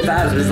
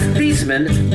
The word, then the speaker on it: Theismann: Joe Theismann's last name is actually pronounced Theismann, but a marketing guy at Notre Dame got him to change it to Theismann, so Theismann rhymes with Heisman. Theismann, Heisman.